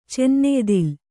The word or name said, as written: ಚನ್ನೆಯ್ದಿಲ್